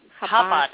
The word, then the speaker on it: Chabad: Chabad.